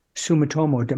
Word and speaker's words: Sumitomo: and then Sumitomo.